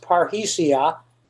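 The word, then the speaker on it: parrhesia: but it's pronounced parhesia. It comes from two Greek words. One means all, pan, P-A-N means all, and R-H-E-O or R-E-O, reo, means to speak.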